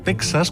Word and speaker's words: Texas: Bueno, lo que está claro es que México se pronuncia México en castellano y no México, que a veces se oye. Aunque cierto es que es más habitual escuchar Texas cuando debería ser Texas.